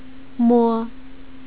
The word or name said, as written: mo